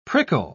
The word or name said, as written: prickle